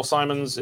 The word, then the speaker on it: Simon: So the latest Governor General Simons is in trouble. Simons, right? That's how you say it? – Simon. Mary Simon.